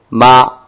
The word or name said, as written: ma